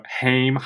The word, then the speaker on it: HAIM: Yeah, it's one of those things where it's like not phonetically, like it doesn't look like how it really sounds. But I just know it as like L'Chaim, like to life, because Chaim means life, and they are three Jewish girls in the valley.